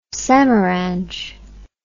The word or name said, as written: samaranch